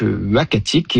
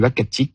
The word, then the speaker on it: Uhackatic: Alors, le nom, ça sera donc Uakatik, et ce nom-là, donc il s'écrit U-H-A-C-K-A-T-I-C, nous a été proposé par le Conseil des Inuits d'Ekunitchit, qu'on a contacté justement pour avoir leur avis.